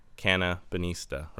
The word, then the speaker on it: Cannabinista: Did I get that pronunciation right? – No. – Cannabinista, right? – Cannabinista, yeah. – Cannabinista. – Yeah. – Okay, I'm just going to say Cannabinista. – Yes, that's perfect.